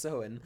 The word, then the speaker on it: Samhain: Pronounced Samhain. That does not look like... – No, it doesn't. It has an M in there.